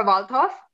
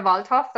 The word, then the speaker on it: Waldhoff: My Facebook page is my name, Jennifer Waldhoff. – Mm-hmm. – If you just like look under Jennifer Waldhoff, then you'll find me.